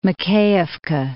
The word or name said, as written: Makiyivka